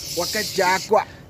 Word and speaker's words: Wakajakwa: Iwakatakwa. Did I say that right? – Yeah, Wakajakwa.